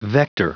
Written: vector

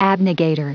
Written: abnegator

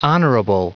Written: honorable

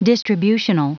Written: distributional

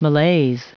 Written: malaise

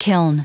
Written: kiln

